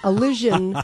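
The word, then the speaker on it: allision: So it's called Illusion, which rhymes with collision. I mean, Elysian, which rhymes with collision.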